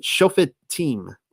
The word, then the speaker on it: Shofetim: You pronounce it Shofetim or Judges.